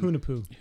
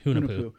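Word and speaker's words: Hunahpu: How would you say Hanapu from Cigar City? And I'm probably saying it wrong. – Hunapu. – Hunapu.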